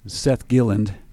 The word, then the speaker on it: Gilland: We have with us Seth Galland. Did I pronounce that right, Seth? Seth Gilland. Why do I want to say Galland? – Are you not French?